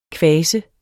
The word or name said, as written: kvase